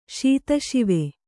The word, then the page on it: ಶೀತಶಿವೆ: śita śive